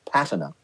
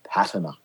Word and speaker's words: patina: So in American, it's patina. But in British English, it's patina.